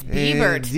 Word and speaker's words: Bibert: It's actually like Bibert. Justin Bieber, but with a T on the end.